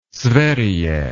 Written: sverige

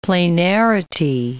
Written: planarity

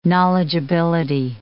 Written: knowledgeability

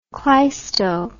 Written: cleisto

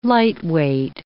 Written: lightweight

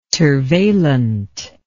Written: tervalent

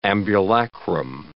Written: ambulacrum

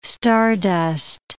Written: stardust

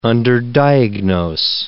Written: underdiagnose

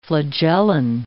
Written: flagellin

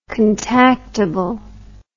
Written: contactable

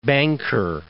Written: West Banker

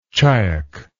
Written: chiack

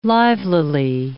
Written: livelily

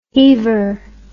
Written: heaver

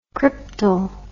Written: Kryptol

